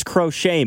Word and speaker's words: Crochet: The T's silent crochet.